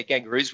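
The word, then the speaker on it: Gangurrus: And Gangouris, am I saying that right? I hope I'm saying Gangouris. – That right. – It's like the Aboriginal saying of kangaroos, I think. – Yeah, Gangouris, which is great.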